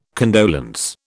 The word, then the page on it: condolence: British English